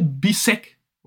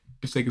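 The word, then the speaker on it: Bisseck: Jan Orel Bisek oder Bissek. Bissek ist, glaube ich, die richtige Aussprache.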